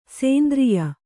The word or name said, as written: ಸೇಂದ್ರಿಯ